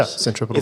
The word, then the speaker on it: centripetal: So physics language, I don't know if I say it right, but centripetal force. – Yeah, centripetal force.